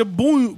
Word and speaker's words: Boeing: Uh, fiquei surpreso, por exemplo, em saber que a empresa Boeing, que sempre pronunciamos com o som de mola, na verdade se pronuncia Boing, Boeing. Boeing, como se tivesse um U no meio.